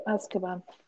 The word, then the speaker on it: Azkaban: Am I saying it right? So. Azkaban? – Prisoner of Azkaban. – Oh, good. Okay,